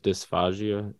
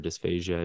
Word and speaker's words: dysphagia: Now, do you notice that these go along with dysphagia or dysphagia? I don't know how you would say that. – Both are completely appropriate ways to pronounce that.